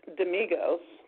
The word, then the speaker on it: Damigos: It's a playful romantic thriller set in contemporary Greece featuring Nick Damigos. Tell me if I'm saying that wrong, Tim. A gay Greek-American. – You're right.